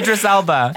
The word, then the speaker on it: Idris Elba: Elvis Elba? – Idris Elba.